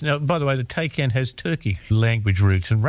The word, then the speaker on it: Taycan: I might have said a Tay, but no, it's not that, is it? – No, it's a Taycan. – Well, T-A-Y-N.